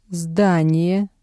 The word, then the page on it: здание: Russian